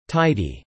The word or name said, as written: Tidy